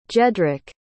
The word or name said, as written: jedrek